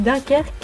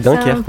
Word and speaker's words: Dunkerque: Sur TMC, à 21h25, c'est Dunkerque. C'est comme ça que ça se prononce. – Oui, c'est ça, c'est Dunkerque, oui.